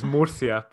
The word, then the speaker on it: Murcia: You mentioned Mercia there, and I definitely didn't say that correctly. Can you say it again for me? Because it sounded awesome when you said it. – I think it's Murcia, but they'll probably pronounce it a different way.